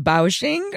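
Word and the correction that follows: Biaoqing (表情): Or how do I say it? – Yeah, Biaoqing. – Clearly, I've met my match in this. – So the term is Biaoqing, and it's usually used as the phrase Biaoqing Bao, which sort of means like bag of Biaoqing.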